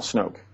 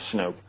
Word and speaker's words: Snook: and the other person is a Napa County deputy sheriff named Hal Snoke, who, or some, you could pronounce it Snook. It's spelled S-N-O-O-K. As far as I know, the correct pronunciation is Snoke.